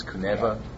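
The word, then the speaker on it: Kunewa: Yeah, it's K-U-N-E-W-A, I believe, even though they pronounce it with a V. It's Kuneva. Yeah.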